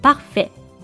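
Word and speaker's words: parfaite: ペ、ア、エー、 F、ア、イ、テと書いてパッフェ。まつのテは発音されませんが、書くときは手を忘れないように気をつけてくださいね。さてこのセパッフェ。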